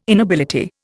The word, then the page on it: inability: Indian